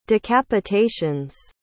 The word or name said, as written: decapitations